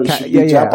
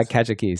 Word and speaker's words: Kajiki: I would probably it's Kajiki, but I'm not sure. – Kajiki. Yeah, Kajiki. So just for people listening, that's K-A-J-I-K-I.